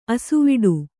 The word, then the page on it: ಆಸೆವಡು: āsevaḍu